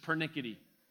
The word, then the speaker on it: persnickety: Their persnickety scrupulosity. He's a British Anglican. Apparently they don't pronounce the S in persnickety. It's just pernickety across the pond there.